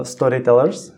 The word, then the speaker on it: Story TLRS: Eh, no, je to samozřejmě název naší digitální agentury, eh, zároveň, zároveň to nutí trochu lidi vyslovovat storytellers, hmm.